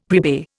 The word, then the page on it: prepay: Indian